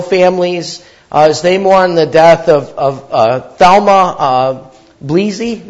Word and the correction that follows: Bleasy: Did I say that right? Blazy, sorry.